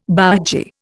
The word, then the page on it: bougie: Indian